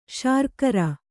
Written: ಶರ್ಕರೆ

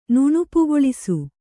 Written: ನುಣುಪುಗೊಳಿಸು

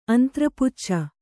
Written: ಅಂತ್ರಪುಚ್ಚ

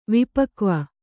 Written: ವಿಪಕ್ವ

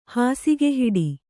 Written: ಹಾಸುಗೆಹಿಡಿ

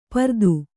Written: ಪರದು

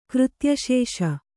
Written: ಕೃತ್ಯಶೇಷ

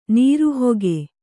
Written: ನೀರುಹಕ್ಕಿ

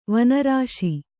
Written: ವನರಾಶಿ

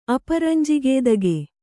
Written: ಅಪರಂಜಿಗೇದಗೆ

